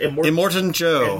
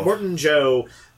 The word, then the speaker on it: Immortan Joe: Immortan Joe. – Immortan Joe, the hideous leader of the bad guys.